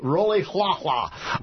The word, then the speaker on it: Rolihlahla: Roli Halahla. – Roli Roli Halahla. – Halahla. – You spell it R-O-L-I-H-L-A-H-L-A. Roli Halahla.